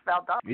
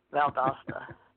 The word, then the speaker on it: Valdosta: Or is it Valdosta? How do you say it? Valdosta? Valdosta, Georgia. – Valdosta? – Seriously? No way. It's Valdosta.